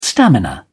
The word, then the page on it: stamina: British English